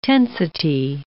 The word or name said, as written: tensity